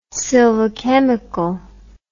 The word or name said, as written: silvichemical